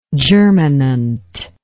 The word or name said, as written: germinant